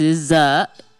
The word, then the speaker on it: SZA: SZA, pronounced SZA, is an acronym from the Supreme Alphabet, which was created by Clarence 13X, founder of the 5% Nation.